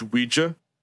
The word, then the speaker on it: Ouija: And he's like, it's pronounced Ouija. – And it's the French oui and the German ya.